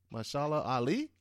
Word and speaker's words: Mahershala Ali: I think it's Mahershala Ali.